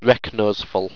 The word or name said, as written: reknoseful